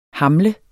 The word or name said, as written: hamle